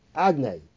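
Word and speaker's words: Adnei (אדני): The Gemara says, in this world, Hashem's name is written, Yud, K, Vav K, but pronounced Adnei.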